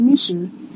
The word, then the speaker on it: Michou: How do you say your name? – It's Michu.